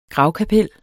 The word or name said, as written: gravkapel